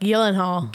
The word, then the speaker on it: Gyllenhaal: How do you say it? – It's like Gyllenhaal. – Gyllenhaal. – It's like Gyllenhaal. – Gyllenhaal. Oh, like it's Swedish or something? – Well, because it is. And it was so interesting.